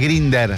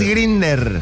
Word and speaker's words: Grindr: Claro, no, como se escribe Grindr, no sé, pero es Grindr, ok. – Así ah, que, es Grindr.